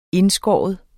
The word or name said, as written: INDSKAR